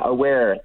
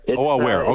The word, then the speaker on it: AAWEAR: I didn't say the specific other group because I wasn't sure how to pronounce it, but it's AAWEAR or AAWEAR. What's the group called? – Yeah, AAWEAR. – AAWEAR, okay.